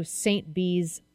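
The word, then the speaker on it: St Bees: Swam from Keswick to St. Bee's Island. Am I saying that right? – You are.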